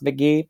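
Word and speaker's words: Mk.gee: But the other one is this guy named McGee is going to be the musical guest, and it's spelled M-K period G-E-E, but it's pronounced McGee, annoyingly.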